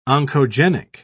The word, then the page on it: oncogenic: British English